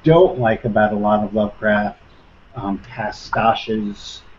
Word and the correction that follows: pastiches: Am I saying that right? – Pastiches.